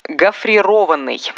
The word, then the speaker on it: гофрированный: Гофрированный. Это причастие образовано от глагола «гофрировать». Ни в одной форме этого слова ударение не падает на «и».